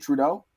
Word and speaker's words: Trudeau: Is it Trudeau? – Yeah.